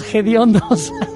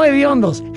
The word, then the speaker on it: hediondo: Que no hediondos, gediondos. Que me encanta. – No existe la palabra gediondo, es hediondo. – Es hediondo, efectivamente. – Se escribe con H, hediondo, no con J ni con G.